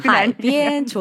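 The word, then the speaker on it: 하이비엔 (海边): 발음은 삼성일성. 하이비엔입니다. – 이제 직접 가르치셔도 괜찮을 것 같아요. – 하이비엔 좋습니다.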